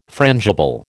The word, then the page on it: frangible: American English